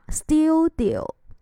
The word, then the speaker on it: studio: 啊，前面那个发音呢，我们要小心。 t 这个音呢，我们要变成的的音去读啊。 studio，它是名词，表示工作室啊，工作室。